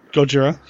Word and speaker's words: Gojira: And then, like, you know, 5-6 years ago, I finally came across a DVD copy of Gojira. I think I'm pronouncing that right. – Gojira. Yeah, that sounds right.